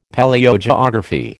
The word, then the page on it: Palaeogeography: American English